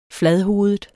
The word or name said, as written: fladhovedet